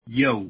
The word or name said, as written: yo